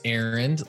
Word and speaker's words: Arend: So my name's Aaron, like running an errand, but it's spelled A-R-E-N-D.